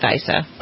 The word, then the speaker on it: Issa: The House Oversight and Government Reform Committee, chaired by Daryl, you pronounce it Issa, I say Issa. Would you say Issa? – Okay. I say Issa, and I'm from California, and so is he, so I would go with Issa.